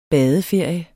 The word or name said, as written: badeferie